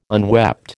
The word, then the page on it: unwept: American English